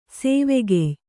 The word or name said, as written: ಸ್ವಕೀಯೆ